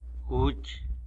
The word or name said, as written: guch